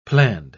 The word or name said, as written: planned